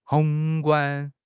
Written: 宏观

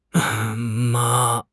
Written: 嘛

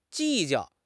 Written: 计较

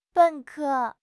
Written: ben3 ke1

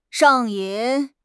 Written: 上瘾

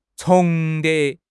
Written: 重叠

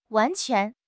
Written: wan2 quan2

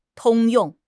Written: 通用

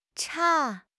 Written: 岔

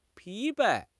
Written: 疲惫

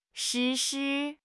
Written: 失事

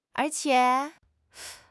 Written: er2 qie3

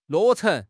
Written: 落成

